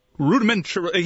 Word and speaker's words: rudimentary: And he's rudimentary. He is an average player. – What just happened there? – I try to say rudimentary. – Rudimentary, and, Mike. That's how you say it. That's how you pronounce it.